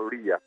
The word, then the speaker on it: Lea: Pošto oni Lea, Amerikanci izgovaraju kao Lija, pa Lija i Nija zvuči veoma slično.